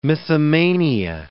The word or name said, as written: mythomania